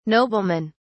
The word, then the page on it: noblemen: British English